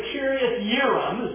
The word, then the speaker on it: Kiriath-Yearim: up here to Kiriath-Yearim, is the way that J is pronounced like a Y.